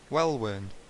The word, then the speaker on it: Welwyn: It's Well Worn? Is that how you pronounce it? – Well Worn City? – Welwyn Garden City? Welwyn. Is that how I say it? – Here, I'm gonna, up-talk it.